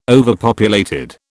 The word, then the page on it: overpopulated: British English